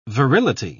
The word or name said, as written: virility